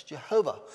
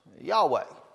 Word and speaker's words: Yahweh: They guessed at an E and O and A makes Jehovah. Well, now what we think the correct pronunciation is, Yahweh.